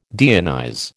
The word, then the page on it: deionize: American English